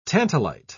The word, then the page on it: tantalite: British English